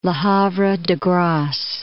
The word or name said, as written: Le Havre-de-Grace